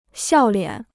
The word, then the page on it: 笑脸: xiào liǎn